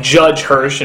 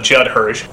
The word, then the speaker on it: Judd Hirsch: There's been a couple of names that, for whatever reason, I could never quite pronounce correctly, like Judge Hirsch instead of Judd Hirsch, Ralph Bashke instead of Ralph Bakshi.